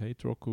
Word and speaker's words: HTRK: Ähm, sie heißen nicht, also man schreibt es nicht so, man schreibt es HTRK, aber eigentlich spricht man es Hate Rock aus.